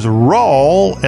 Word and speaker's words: Rawl: his last name is Nall, my last name is Rawl, and they both rhyme with y'all.